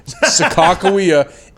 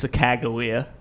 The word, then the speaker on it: Sacagawea: Sacagawea is how I was told that Sacagawea's name is actually pronounced.